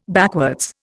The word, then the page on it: backwards: Indian